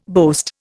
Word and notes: boast: Indian

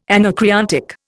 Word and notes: anacreontic: Indian

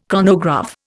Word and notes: chronograph: Indian

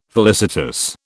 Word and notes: felicitous: British English

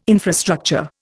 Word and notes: infrastructure: Indian

